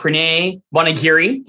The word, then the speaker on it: Pranay Bonagiri: We have Pranay Bonagiri.